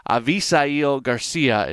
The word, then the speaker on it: Avisaíl García: Avisail Garcia is how I believe it is pronounced, and I will try to do Jim Goulart right and continue to pronounce it that way uh, moving forward.